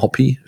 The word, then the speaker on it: Hoppe: Nicht Hoppe, sondern Hoppy, spricht man ihn wohl aus.